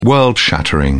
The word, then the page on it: world-shattering: British English